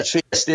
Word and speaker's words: Trieste: And I Trieste. Well, am I saying that right? – Yeah, Trieste.